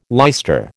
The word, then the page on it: leister: American English